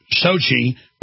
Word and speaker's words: Sochi: Sochi.